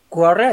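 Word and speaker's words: Quorra: de los hizo Quarre, así se pronuncia.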